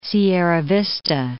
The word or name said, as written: Sierra Vista